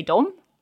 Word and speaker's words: dem: So when we have it as a subject, you're going to recognize this, that we spell it D-E and we say dom. – Mm-hmm. – If it's an object, we spell it D-E-M, still pronounce it dom.